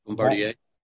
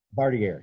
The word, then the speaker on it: Bombardier: Hey, Randy, before you move on, did you mean to say Bombardier or Bombardier? – Oh, I'm sorry. I'm sorry. You're right. I do have my glasses on, but yeah. How did you say that? – Bombardier. – Bombardier. Yeah, that's exactly right. Yeah, okay.